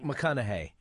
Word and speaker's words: McConaughey: McConaughey, yeah.